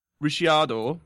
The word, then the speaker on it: Ricciardo: Right, I'm going to pronounce this wrong probably, but Dan Ricciardo. Ricardo. – Ricciardo. Ricciardo quoted yesterday saying he expected to run well in Bahrain.